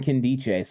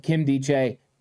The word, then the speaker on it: Nkemdiche: When you're trying to learn players' names, I did believe it was Nkemdiche, but when I was watching and listening to something to get the pronunciation to make sure I did it right, they were calling him Nkemdiche. So I think that a lot of times you're trying to figure it out, but Nkemdiche, perfect.